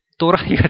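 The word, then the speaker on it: Terai: 그리고 밑에 부분은 이 테라이라는 부분은 이 네팔 발음은 또라이인데 또라이 같이 들리는데 또라이라고 합니다. 또라이라는 부분은 이 평야 지역입니다.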